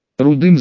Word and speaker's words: Рудый: Рудым звали его пса, и старик произносил его кличку также с ударением на первый слог.